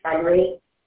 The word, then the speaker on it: Henry: Henry. – Okay, can you spell that for me, please? – H-E-N-R-Y.